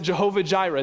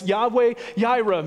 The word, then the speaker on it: Yahweh Jireh: After all, the Old Testament refers to God as Yahweh Jireh. Some pronounce it Jehovah Jireh. It's Yahweh Jireh, meaning the Lord will provide.